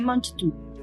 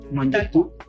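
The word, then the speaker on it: Mangetout: Monsieur Mangetot. – Mangetot, traducido al español como Señor lo come todo.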